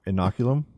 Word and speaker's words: Inoculum: Fear, I'm going to pronounce this wrong, Inoculum? – No, you pronounced it perfectly.